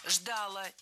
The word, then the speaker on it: ждала: Если бы они победили в борьбе со Сталином, страну ждала бы катастрофа. Мы бы погибли уже в 30-е годы. – Ждала, неверно. Правильный вариант – ждала. Опять же, ударение ставим на окончание.